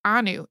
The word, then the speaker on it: ANU: Just so you know, in today's episode, you referenced Anu, the Museum of the Jewish People in Tel Aviv, and you pronounced it wrong. It's confusing that it's all uppercase, but it's not an acronym. It's a word, so it's pronounced like Anu.